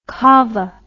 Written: cava

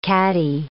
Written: caddy

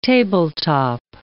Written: tabletop

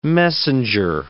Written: messenger

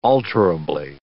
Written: alterably

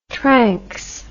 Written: tranx

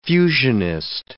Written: fusionist

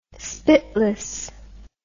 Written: spitless

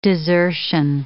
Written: desertion

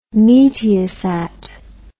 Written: Meteosat

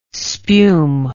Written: spume

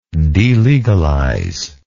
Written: delegalize

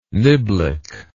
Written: niblick

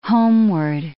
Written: homeward

